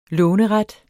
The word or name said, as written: låneret